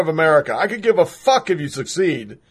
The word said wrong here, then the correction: secede: Succeed. There we go. Secede. No, secede. I'm not even saying it right.